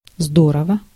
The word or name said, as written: здорово